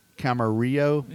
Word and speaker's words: Camarillo: And can I pronounce this? Camarillo, California. – You got it.